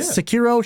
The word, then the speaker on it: Sekiro: Is it Sekiro? Is that how you pronounce that one, Mickey? Yeah, yeah. Sekiro, Shadow Die Twice, and Outer Wilds.